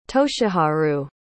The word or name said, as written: toshiharu